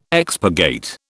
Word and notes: expurgate: British English

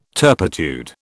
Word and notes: turpitude: British English

